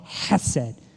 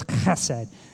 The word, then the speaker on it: chesed: In the Hebrew, it's called chesed. It's really like you're clearing your throat. It's chesed. It's how it's said.